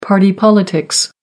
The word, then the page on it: party politics: American English